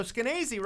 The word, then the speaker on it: Skenazy: Yeah, so Skenazy, rhymes with crazy, was saying, I don't like this song.